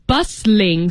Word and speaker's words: bustling: Yeah, bustling, so you don't really pronounce the T in the word. You say bustling.